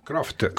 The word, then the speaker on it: Croft: Ma non ho capito, Croft o Croft? – Croft.